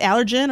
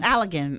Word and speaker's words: Allergan: Is that how you say it? Allergen or Allergan? – Allergan. – I think it's Allergan.